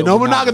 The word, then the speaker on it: Nobunaga: Nobunaga. – Yeah, Nobunaga the samurai.